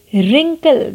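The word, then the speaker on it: wrinkle: रिंकल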